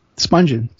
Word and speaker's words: Spungen: Nancy, I forget how you pronounce her last name, Spungen or? – Right. Spungen.